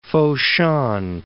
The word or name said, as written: Foshan